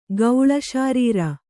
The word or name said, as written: ಗೌಳಶಾರೀರ